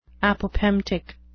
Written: apopemptic

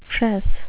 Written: fress

